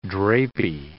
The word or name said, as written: drapey